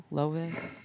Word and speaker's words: Louisville: No, did I say that right? – No, Louisville. You didn't. Louisville. – Louisville. – Louisville. – Louisville. – Louisville. – It's not Louisville. – Like low? How low can you go?